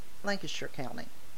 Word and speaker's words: Lancashire: Is it Lancaster or Lancashire, the county? – Lancashire County. – But it's spelled Lancaster.